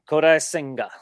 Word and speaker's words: Kodai Senga: And your boy was Kodai Senga. Is that how you say it? – Kodai Senga.